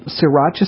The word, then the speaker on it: sriracha: All right, let me spell it for you, because it's spelled S-R-I, like Sri, like mm-hmm. Sri Lanka. – Okay. – S-R-I-R-A-C-H-A.